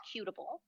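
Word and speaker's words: Kuhteubl: The pronunciation is Cutable. And it is an Austrian last name.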